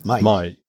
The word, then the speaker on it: Mai: I don't know if I'll get the pronunciation right, but it's Mai. Is that how to pronounce it? – Mai. – Mai.